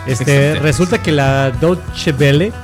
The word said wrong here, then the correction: Deutsche Welle: Deutsche Welle. Es que lo pronuncias como si fuera italiano.